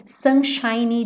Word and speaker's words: sunshiny: Não esquece desse Y, tá? Sunshiny, porque ele tá fazendo o adjetivo, lembra que eu falei pra você? Sunshine, termina com E e o E é mudo, seria o brilho do sol, tá? E sunshiny tá fazendo adjetivo, então esse Y tem que aparecer. Sunshiny day. Não é a sílaba tônica, mas tem que aparecer.